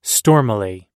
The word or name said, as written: stormily